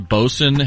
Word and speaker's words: Boatswain: That's word, considering it's pronounced Bosun, has so many unnecessary letters in the spelling, it's ridiculous.